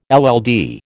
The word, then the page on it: LL D: American English